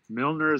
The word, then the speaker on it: Mjolnir: Mjolnir is how you say it.